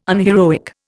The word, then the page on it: unheroic: Indian